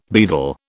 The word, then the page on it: beadle: British English